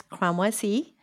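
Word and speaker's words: Cramoisi: You say it right. It's Cremoisi, so it's okay.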